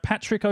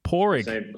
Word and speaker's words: Padraig: And going a completely different area of knowledge, I'm sure, as a brewer, it's hello to, and I'm not sure if I'm pronouncing this right, Patrick O'Feel? – It's just Padraig O'Feel. Padraig O'Feel. That's right. – Padraig. – Padraig's the name.